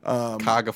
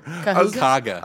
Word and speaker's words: Cuyahoga: Cuyahoga Falls. – Kaga Falls for the people who live there. – Yeah. Cuyahoga. – Kaga.